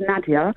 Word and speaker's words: Nadya: So my now husband, on our wedding day, so the priest was going through our vows, and my name's pronounced Nadya.